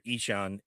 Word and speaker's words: Ixan: Just because it's an audio format here, Ichan is how you pronounce IXAN.